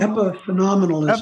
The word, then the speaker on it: epiphenomenalism: So there's, I don't even know how to say this word, epiphenomenalism? – Epiphenomenalism. – Epiphenomenalism. Yeah.